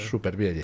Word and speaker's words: Supervielle: Nosotros lo hemos españolizado ya y lo pronunciamos tal cual. – Supervielle. – Tal cual se lee, sí.